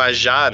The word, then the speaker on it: Aja: Aliás, já te pergunto, você pronuncia Aja também, né? – É, a, eu sei que os americanos pronunciam, alguns pronunciam ajá, né? Então, porque é francês e tal, mas eu tô falando aja porque foi como eu sempre li.